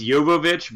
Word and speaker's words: Jovovich: It's technically it's Jovovich, but most people just say Jovovich in America.